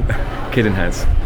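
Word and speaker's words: Cadenhead's: Cadenheads.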